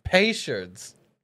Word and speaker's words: Peychaud's: It's spelled P-E-Y-C-H-A-U-D apostrophe S. – Fachard. – I believe that can be correct. I'm not doubting that that's... Now I'm sorry. Peychaud's bitters. – Peychaud's.